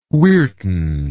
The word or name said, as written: Weirton